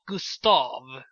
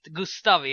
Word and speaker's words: Gustav: Vi kommer diskutera, du uttalar för övrigt mitt namn så som äldre människor gör, alltså med betoning på av, Gustav. Alltså sådär, det är lite fint. Gustav är för alla andra i din generation.